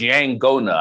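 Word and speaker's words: yaqona: I think it's called Yagona or something like that. Y-A-G-O-N-A. I may have mispronounced it, but that's what it's. Oh, it's Yangona, I think is how they pronounce it.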